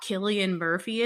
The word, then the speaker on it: Cillian: And I'm saying it right this time. I know if you ever listened to our 28 Days Later episode, you probably heard me mispronounce Cillian Murphy's name multiple times.